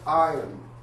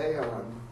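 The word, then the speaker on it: ion (αἰών): It's the word, it's pronounced ion, but it looks like it'd be aeon. It's pronounced I-O-N with a capital I, or the long I.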